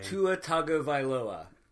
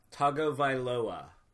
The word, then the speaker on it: Tagovailoa: Tua Tagovailoa. – Tagovailoa? – Tagovailoa.